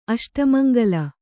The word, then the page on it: ಅಷ್ಟಮಂಗಳ: aṣtamaŋgaḷa